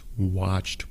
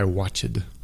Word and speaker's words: watched: No son dos sílabas en este caso. Es una sola sílaba y es un poco difícil porque son como muchos consonantes juntos. ¿Sí? Watched. En una sola sílaba. Watched.